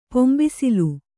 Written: ಪಂಬಸಲೆ

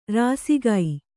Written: ರಾಸಿಗೈ

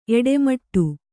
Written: ಎಡೆಮಾಡು

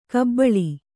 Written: ಗಬ್ಬಲು

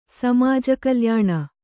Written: ಸಮಾಜಕಲ್ಯಾಣ

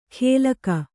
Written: ಗೆಲ್ಕ

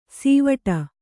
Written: ಸಿವಟ

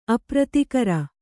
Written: ಅಪ್ರತಿಕರ

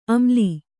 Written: ಅಮ್ಲಿ

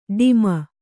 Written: ಡುಮ್ಮು

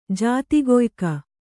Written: ಜಾತಿಗೊಯ್ಕ

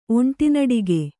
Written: ಒಂಟಿನಡಿಗೆ

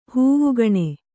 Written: ಹವಾಗುಣ

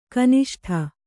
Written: ಘನಿಷ್ಠ

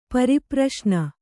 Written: ಪರಿಪ್ರಶ್ನ

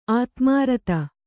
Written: ಆದಿಮೂರುತಿ

